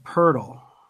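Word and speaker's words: Pöltl: They also got Marco Belinelli, Dante Cunningham, and uh, Jakob Podol in the Raptors trade. I don't know how to say his name either. – And Lonnie Walker, the, it's like a cat does. What does a cat? What's what? What noise does a cat make? – Uh, Purtle. – There you go.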